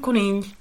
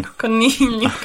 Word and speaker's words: conill: Un conill. Alex looked at me like, what? It's a difficult sound to make. – Un conill. Conill. Yeah, even I find it a bit tricky to pronounce.